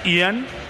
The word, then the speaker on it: Ian: Me gusta tu pronunciación británica, es Ian. – ¿Ian? – Ian. Ian. Ian Poveda.